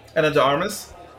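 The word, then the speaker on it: Ana de Armas: Anna de Armas.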